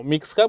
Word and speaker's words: Mixja: Eh, se llama Misja, yo lo pronuncio como Mixja porque lleva X en vez de S.